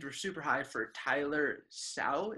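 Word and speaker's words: Shough: Rhymes with duck. It's shuck. And shuck.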